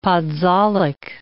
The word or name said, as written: podzolic